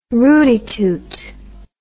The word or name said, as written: rooty toot